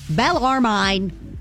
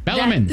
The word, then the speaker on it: Bellarmine: Okay, B-E-L-L. – Yes. – A-R. – Yes. – M-I-N-E. – There you go. – Bellarmine. – Bellarmine.